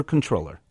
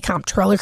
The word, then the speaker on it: comptroller: Is it comptroller or controller? – What is it, like potato, potato? So technically, it's controller, but lots of people say comptroller because that's how it's spelled.